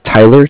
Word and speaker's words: Tyler: My name is Tyler, T-Y-L-E-R. And I'm calling with Cardiff. It's spelled C-A-R-D-I-F-F, as in Frank.